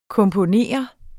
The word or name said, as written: komponere